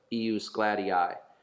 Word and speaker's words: ius gladii: Yes, EUS is IUS, and it's GLADII, three syllables. It's G L A D I I, IUS GLADII, which is Latin for the right of the sword.